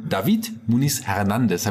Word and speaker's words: David Muniz-Hernandez: Und David Muniz Hernandez.